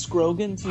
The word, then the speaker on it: Scroggins: And Mike Scruggins. Terrifying. – Yeah. – So, I can't believe that's pronounced Scroggins. He's a lefty. – Yes, he is Scroggins.